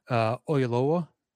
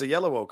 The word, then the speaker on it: Oyelowo: I think you're thinking of David Oyelowo.